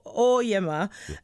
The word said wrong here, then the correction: Oryema: um, is that how it's pronounced? – Uh, you can choose. – No, that. no, no, no. Tell me how you would like it to be pronounced because I'm fussy about my name. So okay, Wilson Oryema. – Oryema, Oryema.